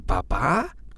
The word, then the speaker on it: papá: Pero papas es como mi papá. – Bueno, suena igual. Papá y papa suena igual, pero papá es Papá. – el papá. Depende cómo lo pronuncias.